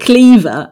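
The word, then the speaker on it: Kleve: But I always forget that because because we pronounce it Cleaver and we don't say Anne of Cleaver, we say Anne of Cleves.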